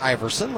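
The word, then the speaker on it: Aeverson: Iverson Arteaga, who, you know, it's pronounced like Iverson, like the last name of Allen Iverson, but it is spelled different.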